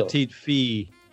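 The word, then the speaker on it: Petit Fils: Petite Fee. Petite, I think you don't pronounce Fee. The L or S.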